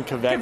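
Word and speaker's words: Kveik: I think it's pronounced Kvik. – Yes. – I don't know. I don't know how to pronounce it. We call them Kviks. Chris is calling Kvik.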